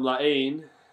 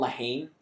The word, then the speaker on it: La Haine: My pick is 1995 film La Haine. Hate. – You got it. Because I always want to call it La Haine. – And I always mispronounce it. I didn't know the H was silent until I looked into it.